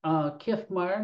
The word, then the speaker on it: Kiffmeyer: My name is Michael Kiffmeyer, and that's spelled K-I-F-F, two F's like Frank, M-E-Y-E-R.